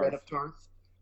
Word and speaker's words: Brienne of Tarth: The surprising one would be Gwendolyn Christie, who plays, I'm going to screw up the pronunciation, but Brienne of Tarth. – Yes, yep. – Brienne of Tarth. – Brienne of Tarth.